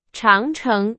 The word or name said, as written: 长城